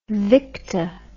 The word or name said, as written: Victa